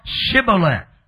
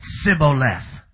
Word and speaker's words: Shibboleth: They said unto them, Say now, Shibboleth. And the Bible said, they said, Sibboleth. Which they left the H. Amen. They could not pronounce the letter H.